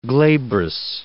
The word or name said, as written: glabrous